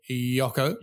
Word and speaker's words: Jocko: I mean, what is so exciting about uh, some call him Jocko? Uh, if you use the uh, correct Dutch pronunciation, it's actually Jocko.